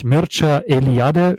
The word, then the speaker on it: Mircea Eliade: ist, also spricht sich Mircea Eliade, schreibt man Mircea Eliade.